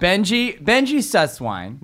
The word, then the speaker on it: Benjy Susswein: Before I can start, Benji Susswine.